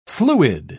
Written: fluid

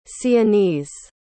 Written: sienese

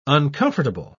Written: uncomfortable

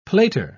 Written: plater

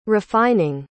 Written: refining

